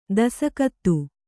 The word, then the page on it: ದಸಕತ್ತು: dasakattu